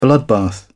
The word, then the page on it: bloodbath: British English